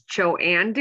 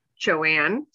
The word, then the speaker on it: Joanne: My name is Joanne DeVault, Joanne, spelled J-O-A-N-N-E, and then Devault, as in I put my money in Devault, D-E, capital V-A-U-L-T.